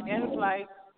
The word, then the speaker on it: Enflyte: It's on the In Flight A.